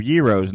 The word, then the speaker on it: gyro: Now, if you're unfamiliar with the word gyro, it's probably because a lot of people pronounce it gyro, G-Y-R-O-S. I was corrected by one of my good Greek buddies, and he said, no, it's pronounced gyro.